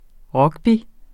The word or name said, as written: rugby